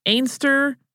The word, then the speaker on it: Anstruther: It is not 100% clear whether she pronounced this Ainster, Anstruther, or some other variation. She was from Scotland, and there is a town in Scotland where locals say Ainster, but everyone else does not.